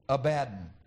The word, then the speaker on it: Abaddon: But if you transliterate what those Hebrew radicals look like, you would pronounce it Abaddon.